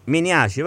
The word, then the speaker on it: Miniaci: Buonasera a lei, comunque se posso correggerla è Miniaci. – Miniaci, va bene, quindi l'accento diverso.